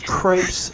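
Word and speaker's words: crêpe: We talked about crepe, which is Americans say crepe. – I was going to say, we say better known as crepe. – Right. – You just right. blew it for me. I've been saying crepes for years. – That's pronounced crepe.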